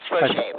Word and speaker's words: Roget: L-O-C-K-A-R-D. – That's R-O-G. – Yes. – R-O-G is in George. R-O-G-E-T. – Yes. And that's pronounced Roger, right? – It is. I thought you said R-O-J because some people put a J in there instead of the G, trying to approximate the phonetics of it.